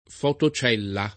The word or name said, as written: fotocellula